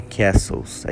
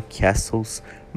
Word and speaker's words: castles: Outra palavra que vale muito a gente falar é o que a gente vê aí, castelos, é castles, é igual listening, ok? O T é mudo, então é castles, muscle, beleza?